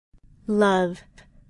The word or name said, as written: love